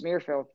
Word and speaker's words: Muirfield: But, out of that particular group, I will say, if you look at the design of or the layout of was it Muirfield? Is that am I saying it right? – Yep, my accent's coming out. Nope, you're saying it right. It's Muirfield.